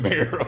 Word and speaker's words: Mero: I can't never pronounce their names. – It's Jesus and Mero, nigga.